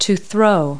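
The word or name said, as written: throw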